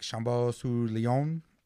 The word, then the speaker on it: Chambon-sur-Lignon: It's called like Chambon-sur-Lignon it's it's in That's actually really good fucking pronunciation.